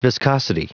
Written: viscosity

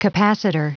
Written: capacitor

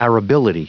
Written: arability

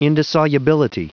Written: indissolubility

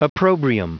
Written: opprobrium

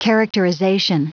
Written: characterization

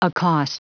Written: accost

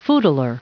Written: footler

